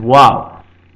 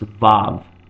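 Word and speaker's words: wow (ו): Wow is the next word, and yes, it sounds like the English expression wow, wow. It is wow, commonly modernly pronounced vav, but actually originally it was pronounced as a W sound, wow, as it is in Arabic and Aramaic.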